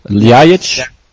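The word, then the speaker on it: Ljajić: Ljajic? – Ljajic.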